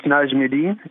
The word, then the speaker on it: Najmadia: It's Najmadia. It's an Arabic name.